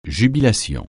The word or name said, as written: jubilation